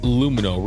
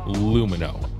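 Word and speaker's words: Lumineux: That's Lumino. Remember, spelled with an X, so you can X out the harm. Lumino. That's L-U-M-I-N-E-U-X.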